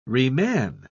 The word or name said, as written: reman